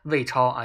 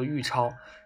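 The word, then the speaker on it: 尉超: 然后这个投稿就是说，他高中的时候有一个同学叫做魏超啊，就叫做玉超。然后这个字呢是多音字嘛。然后一般来说，他的名字啊，别人第一次念的时候就很容易把玉念成嗯魏啊，这个我深有体会，因为我第一次见那个她闺蜜的时候，我也是把它念作了魏，后来呃也是第一次在初中的时候知道了，就是说这个是个多音字啊，就是按做姓氏的话，它念作玉，然后。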